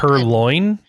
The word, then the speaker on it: purloin: Purloin, you mean? – Purloin. – Perloon. Were you trying to combine? – I thought that's what it was, because that was the joke, but apparently I've been mispronouncing it the whole time.